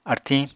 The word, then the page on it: artiñ: Apatani